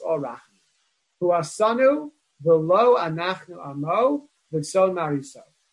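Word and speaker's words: V'lo (ולו): We need to take note here that the word V'lo is a ksiv and a kri, it's written Vav Lamed Aleph, but it's pronounced Vav, it's pronounced Vav Lamed Vav.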